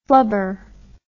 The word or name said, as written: flubber